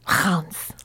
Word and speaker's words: Reims: I was living in a place called, it's written R-E-I-M-S. But it's pronounced like Hans.